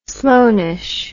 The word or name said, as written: sloaneish